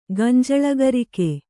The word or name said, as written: ಗಂಜಳಗರಿಕೆ